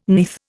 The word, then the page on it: neath: Indian